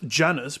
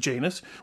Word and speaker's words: Janus: Which looks like it's pronounced Janus, but it is actually Janus, which rhymes with anus, which is why we are both a pain in said anus.